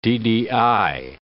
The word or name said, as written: ddI